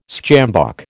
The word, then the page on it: sjambok: American English